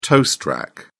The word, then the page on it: toast rack: British English